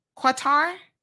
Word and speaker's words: Qatar: She said emphasis is actually on the first syllable. So Qatar. Oh, Qatar.